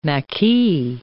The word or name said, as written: maquis